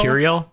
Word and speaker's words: Mercurial: I've heard of these companies having to take things like Git and Miracle and how do you say that? Mir- miracle? – Miracle? Mercurial? Mercurial. – Mercurial. I always goof that up.